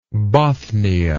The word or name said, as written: Bothnic